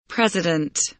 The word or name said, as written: president